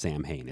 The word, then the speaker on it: Samhain: Now, if it sounds familiar or something, you maybe have seen it spelled before, and it's S-A-M-H-A-I-N. It's Gaelic. It's not pronounced Samhain. It's pronounced Samhain.